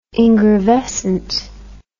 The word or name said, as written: ingravescent